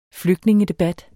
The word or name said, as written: flygtningedebat